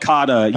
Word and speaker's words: Kata: Is it Kata? Did I say it right? – It's called Kata. Yeah, K-A-T-A.